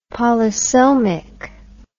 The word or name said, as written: polysomic